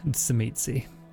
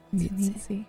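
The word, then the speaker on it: Tzimisce: For the benefit of everyone, the only correct way to say it is Tsimitsi. – Tsimitsi.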